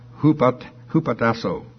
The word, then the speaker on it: hupotasso: H-U-P-O-T-A-S-S-O. And the way they've got it pronounced here is, looks like hupatasso.